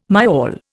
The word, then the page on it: myall: Indian